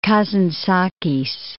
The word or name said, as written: Kazantzakis